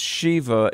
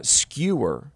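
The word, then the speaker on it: Sceva: Well, I don't mean to be trite or something, but it's very often pronounced Shiva. In the Greek, it's pronounced skewer, and these guys are about to get skewered.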